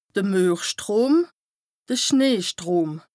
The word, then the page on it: de Muerstrom / de Schneestrom: Bas-Rhin; Strasbourg